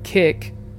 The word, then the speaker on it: Kik: The website Kik, is that how you say it right? – Yeah.